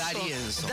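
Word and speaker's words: D'Arienzo: Juan de Arienzo. ¿Está bien pronunciado Arienzo? – Darienzo. – Juan Darienzo. Sabía que lo estaba pronunciando mal.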